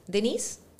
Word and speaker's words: Denisse: Denisse is a French name.